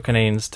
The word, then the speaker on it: Canadiens: Because they're not the Canadians. They're the Canadiens. Right? Because that's how you say it.